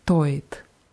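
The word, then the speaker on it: stoit (стоит): Und bei stoit ist es wichtig, die Betonung ganz deutlich auf O zu machen, weil stait heißt was anderes. Stait heißt steht. Es ist zwar aus dem Kontext klar, was man wissen will, trotzdem sollte man die Betonung beachten.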